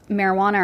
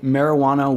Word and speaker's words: marijuana: Yeah, because it's like, I think marijuana was the term that Mexicans would call it back in the day, because it's like the J is pronounced H, so it's like Spanish.